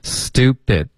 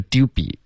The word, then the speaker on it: stupid: Stupid, 不是 dupy, OK? 你这发现太萌了吧。 – 首先是 du 的这个发音是 du, 不是 du, 然后 pit, 不是 be, OK?